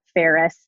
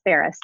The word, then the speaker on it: Faris: And it's Paula Faris. My last name is spelled just like the city of Paris with one R. – One R. – With an F like Frank, Paula Faris.